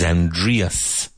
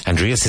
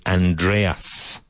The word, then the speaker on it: Aindrias: People tend to think my name is Andreas.